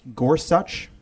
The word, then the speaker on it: Gorsuch: First of all, I did look up how to pronounce it properly, and he did say in his testimony that it's pronounced Gorsuch. That is so frustrating. It's a spondy, right? The emphasis is the same on both the first and second syllable.